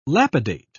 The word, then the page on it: lapidate: British English